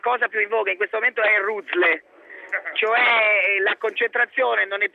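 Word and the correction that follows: Ruzzle: Si chiama Razzle, pazza. Poi comunque lasciamo perdere. Razzo, comunque lasciamo perdere. – Vabbè, però si scrive Ruzle, no? – Sì, beh, ho capito, sì, però la scrittura e la dicitura sono due cose diverse.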